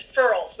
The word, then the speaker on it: furls: They get goosebumps or goosies, or it's also known as furls, which rhymes with curls, F-U-R-L-S.